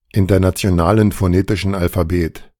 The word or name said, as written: Internationalen Phonetischen Alphabet